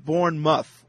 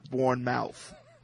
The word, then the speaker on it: Bournemouth: Robert from UK says it's pronounced Bournemouth, not Bournemouth. Just Bournemouth. – Wait a minute. It's all one word? – Yeah, Bournemouth.